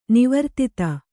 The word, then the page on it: ನಿವರ್ತಿತ: nivartita